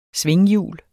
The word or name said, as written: svinghjul